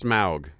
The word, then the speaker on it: Smaug: I think it's pronounced smaug. So I learned that in my Elvish class.